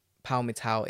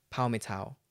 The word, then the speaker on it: Palmital: Palmital. Correct pronunciation, yeah? That's good. Okay. – Yeah. Yeah. It's awesome. It's awesome. You're next. – Okay. Listeners, his signal is gone a little bit, so I've just turned him down on my mixing desk. I'm sure he'll come back. But Palmital, when I look at it, it's spelled Palmital. But I made sure that I can pronounce it before we started. It's Palmital.